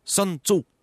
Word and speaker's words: Sun Tzu: Sun Tzu. – Vous le prononcez extraordinairement bien, dit ça, je suis impressionné.